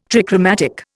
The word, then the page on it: trichromatic: Indian